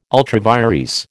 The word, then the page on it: ultra vires: American English